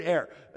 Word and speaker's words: err: The word err, now it's properly pronounced err, but nobody's going to say that in their right mind.